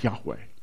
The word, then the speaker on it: Yahweh: In Exodus chapter 3, verses 14 and 15, those four letters, tetragrammaton, YHWH, we pronounce it Yahweh.